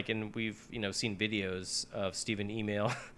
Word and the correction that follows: Amell: Amell, dude. His name is not pronounced Email.